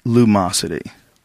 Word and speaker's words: Lumosity: Luminosity. – How do you say it? – Luminosity. – Illuminosity. – It's too much for Brian. He's like, this is too complicated. Lumosity. Say that.